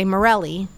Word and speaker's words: Mirelys: So when I say my name in English, I always say Mirelys.